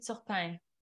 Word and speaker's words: Turpin: In French, it would be Turpin.